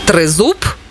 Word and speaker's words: тризуб: тризуб чи тризуб? Як правильно? Наголос на У.